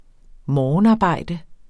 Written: morgenarbejde

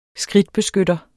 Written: skridtbeskytter

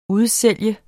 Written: udsolgt

